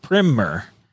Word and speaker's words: primer: However, when the word primer is used as an introduction, a narrative explanation of some topic, an educational pamphlet or short book, it is pronounced primer, as in trimmer, dimmer, etc.